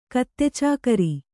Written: ಕತ್ತೆಚಾಕರಿ

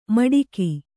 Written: ಮಡಿಕಿ

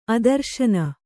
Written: ಆದರ್ಶನ

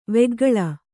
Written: ವೆಗ್ಗಳ

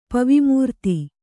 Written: ಪವಿಮೂರ್ತಿ

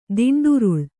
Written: ದಿಂಡುರುಳು

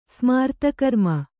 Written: ಸ್ಮಾರ್ತಕರ್ಮ

